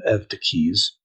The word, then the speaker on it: Eutyches: Because someone can take that language, in this case, the heretic Eutyches, or for those of you who belabor me about my pronunciation, Eutyches, though don't ask me to say mater theon, it's mater theou, right?